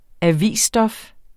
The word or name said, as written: avisstof